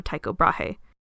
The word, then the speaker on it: Tycho Brahe: You can blame the name on 16th century astronomer Tycho Brahe, that's the original Danish pronunciation of Tycho Brahe, because he saw a new dot in the night sky.